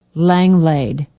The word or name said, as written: Langlade